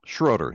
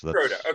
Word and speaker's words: Schroader: Schroader, yes. Schroader, okay. That's how it's pronounced, yes.